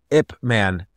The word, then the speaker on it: Ip Man: And it's called Ip Man. It's spelled I-P space man, M-A-N.